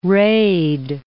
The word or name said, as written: rayed